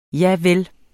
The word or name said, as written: javel